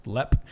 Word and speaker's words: Leap: Leap Castle, pronounced Lep.